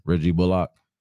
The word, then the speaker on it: Reggie Bullock: Reggie Bullock.